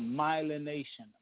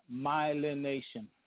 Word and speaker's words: Myelination: But the point is, that's all to come back to this, that it's called myelination. Myelination. I believe it's spelled M-Y-L-E-N-A-T-I-O-N.